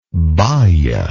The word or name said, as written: baya